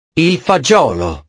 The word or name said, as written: il fagiolo